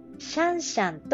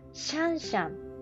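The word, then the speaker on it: 香香: 次、 2017 年 6 月、東京上野の上野動物園でジャイアントパンダが生まれ、シャンシャンと名付けられた。これはシャンシャン、中国の、読み方ですかね。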